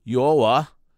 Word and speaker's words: Yoa: And then it was followed by the 2-horse Cinnamon Popcorn here, nearly 10-1, and that's Yoa. Did I say that right, Brian? Yeah, thank you. And that was Yoa with, let's see here, with Fridley.